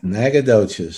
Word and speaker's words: Nacogdoches: Austin, Nacogdoches. – Nacogdoches. – Nacogdoches. – Yes, said it right. Can you spell it right? – The words in N-A-C-A-G-O.